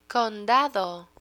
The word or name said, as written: condado